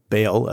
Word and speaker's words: Baal: Because he tore down the altar of Baal, Baal, as is sometimes pronounced, this altar that his father had erected.